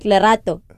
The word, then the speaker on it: Lerato: I was like, say my name properly. He's like, how? And I'm like, Lerato. He's like, how? I was like, say it properly. He's like how? I was like, say it, like with that deep.